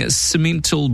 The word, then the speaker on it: Simmental: 20 yearling Simmental bulls, that's how you pronounce it,